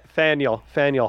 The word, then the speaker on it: Faneuil: And looking here at the Northeast, there was one at Fanui Hall at Boston. So that's probably pretty close. Fanui? Is that not pronounced? – Faneuil Hall. – Faneuil, okay. I'm a tourist.